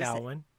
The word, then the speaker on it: Samhain: Samhain. – Samhain, thank you. I got a short memory here. Samhain, because it isn't even remotely spelled like that.